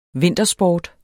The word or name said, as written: vintersport